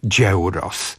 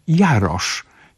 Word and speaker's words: Jarosz: ale jej nazwisko wymawia się Dzieuros. Czy państwo daliby wiarę, że ona po prostu po polsku powinna być wymawiana jako Jarosz?